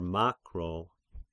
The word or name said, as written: Makro